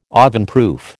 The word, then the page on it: ovenproof: American English